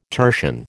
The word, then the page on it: tertian: American English